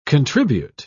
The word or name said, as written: contribute